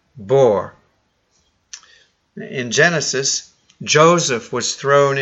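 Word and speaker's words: bor (בור): It's pronounced bor. I don't know how to spell it in Hebrew, but it's just bor, which means only a hole.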